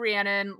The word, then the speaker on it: Rhiannon: So, and I am going with the Rhiannon like Americanized pronunciation. And I know that's like not quite correct, but if it's good enough for Stevie Nicks, it's good enough for me.